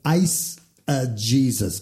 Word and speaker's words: eisegesis: The opposite of that is called eisegesis. Eisegesis. That's spelled E-I-S-E-G. E-S-I-S, eisegesis.